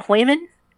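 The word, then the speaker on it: Heumann: and her name was Judy I guess you pronounce it Hoyman. It's German.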